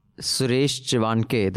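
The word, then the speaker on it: Suresh Chavhanke: One of them is our executive editor and colleague Atul's interview with Suresh Chivanke. That's how you pronounce it, right? – Yeah.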